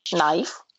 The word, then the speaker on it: knife: Когда K стоит перед согласным в начале слова, то она не читается. В таких словах, как know, knowledge, knock, knife, Knight, как рыцарь, and knee, которая коленка.